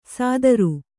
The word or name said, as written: ಸದರು